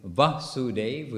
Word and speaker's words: Vasudev: And when Devaki was going to be married, then Kamsa arranged a big festival, of course, for the marriage of Devaki with Vasudev, with a short A.